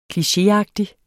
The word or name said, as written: klichéagtig